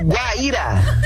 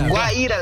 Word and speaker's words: Guaíra: Ô, Léo, é Guaíra. – Guaíra. – É Guaíra, Léo. – Ah, Guaíra, tá. Foi mal. Eu falei errado aqui.